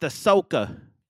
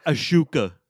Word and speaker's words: Ahsoka: And it's pronounced Ahsoka, not Ashuka.